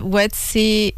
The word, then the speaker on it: WEDC: pero Wetsy, nosotros los caminamos de la mano paso a paso. – Acá estoy en la página web Wetsy Biz, o sea, se escribe WEDC.